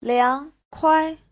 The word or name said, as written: liang2kuai5